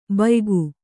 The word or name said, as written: ಭೂಯುಗ